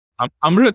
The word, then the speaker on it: Amrut: How do you pronounce A-M-R-U-T in Indian? – Amrut.